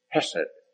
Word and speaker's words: chesed: It's called hesed. As we would spell it C-H-E-S-E-D.